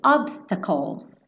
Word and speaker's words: obstacles: Esse obstacles. Calls, tá? Esse CL, o E não vai ser pronunciado. Então, calls, bota a língua lá no céu da boca no L, tá?